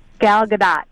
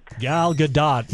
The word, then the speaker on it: Gal Gadot: Gal Gadot. – Gal Gadot, Wonder Woman, indeed.